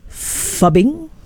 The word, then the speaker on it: phubbing: Parents, do you know about fubbing? – Fubbing. – Yes. And parents have been issued a warning about fubbing. It's spelled as P-H-U-B-B-I-N-G. Basically, it's phone and snubbing.